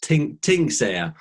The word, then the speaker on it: Tingsager: But it's pronounced Tingsager.